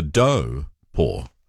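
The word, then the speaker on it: Taupō: Now, people grow up saying Taupo. Now, that's incorrect. So I have no qualms about saying Taupo. But I am also interested as to how Taupo is evolving into not being spelt with a T, but being spelt with a D, in terms of how it sounds, because it's evolving into Do-po rather than Taupo.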